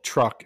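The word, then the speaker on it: Truk: But a lot of people that end up pronouncing that in English call it Truck and know it better as Truck Lagoon. That's T-R-U-K, not T-R-U-C-K.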